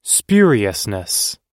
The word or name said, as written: spuriousness